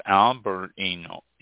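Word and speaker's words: Albariño: It is pronounced, it's spelled A-L-B-A-R-I-N-O with a little squiggly mark above the N. I don't know, there's a name for those things, but I don't know what they are. It's pronounced Albarino, A-L, Al, Bar, B-A-H-R, E, E-E-N, Yo, Y-O, Albarino.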